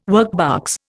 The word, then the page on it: workbox: Indian